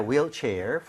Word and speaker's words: wheelchair: 발음할 때 휠이 아니라 뭐라 그랬죠? – A wheel. – Wheel, right? – 아. – 우리가 흰색도 화이트라고 안 하죠? – White. – White이라고 하죠. 그래서 네. – H가 없는 느낌으로 발음할 때 wheel chair 이라고 하는 게 맞습니다.